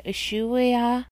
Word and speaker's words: Ushuaia: This penitentiary, Ushua, I don't know how to pronounce it, Ushuaia, U-S-H-U-A-I-A Penitentiary, would become known as one of the harshest prisons in the world.